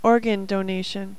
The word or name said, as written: organ donation